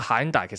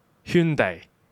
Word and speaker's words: Hyundai: 然之后咧，跟住就有个好靓嘅女士走出嚟，同佢讲个喊大其实系读圈地。然之后佢哦圈地，然之后就哦就系、是、个正确读音就系圈地啦，就系、是、咁样。